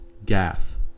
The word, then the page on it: gaffe: American English